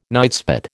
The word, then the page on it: nightspot: American English